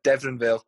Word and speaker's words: Deveronvale: There's also been loans at Dumbarton, and I can't pronounce this, but is it Deronavale? Deveronvale. That's the, that's what I meant.